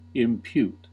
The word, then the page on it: impute: American English